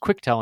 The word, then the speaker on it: Quectel: But because of that, I actually got a evaluation board from Quectel, which I found out is pronounced Quectel and not Quectel, Q-U-E-C.